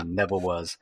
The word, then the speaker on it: Nevawuz: It was called the Land of Neva Was, and it was spelled NEVAWUZ, like phonetically, you know, Neva Was.